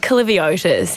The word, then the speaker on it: Kaliviotis: It's actually phonetic. It's just Kaliviotis, how it's spelled.